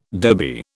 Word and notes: dhobi: British English

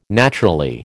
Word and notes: naturally: American English

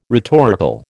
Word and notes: rhetorical: American English

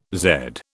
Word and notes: zed: British English

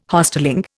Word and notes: Hostelling: Indian

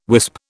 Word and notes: wisp: British English

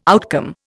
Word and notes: outcome: Indian

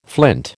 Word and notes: flint: American English

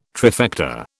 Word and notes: trifecta: British English

